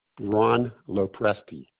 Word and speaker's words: Ron Lopresti: Ron Lopresti. LOPRESTI. – LOPRESTI? – Correct. – Okay, and the first name was Ron, R-O-N?